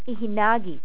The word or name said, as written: ihinagis